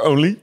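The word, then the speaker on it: Onley: Eh, die van, van, Oscar Only? Ja, van Team DSM Firmenich PostNL. Nice. Zeg ik het goed? Ja, hè?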